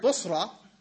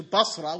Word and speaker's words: Busra: Anyway, I mean, in his ignorance, he took the word Busra and he mispronounced it to Basra, which is a city in Iraq.